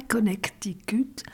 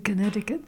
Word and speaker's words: Connecticut: Il faut le prononcer comme il faut, c'est pas Connecticut, c'est Connecticut.